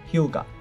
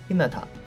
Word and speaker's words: 日向: 比如《火影忍者》中的日向雏田，其中姓氏日向就是向着太阳的意思，源于日本古分国之一的日向国，在火影中被作为姓氏读作 Hyuga，它的日语训读是 Hinata，而雏田的发音也是 Hinata。